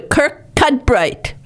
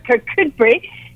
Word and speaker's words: Kirkcudbright: Yeah, there's quite a few places that have interesting pronunciation that doesn't exactly match the spelling, but Kirkcudbright is one of them.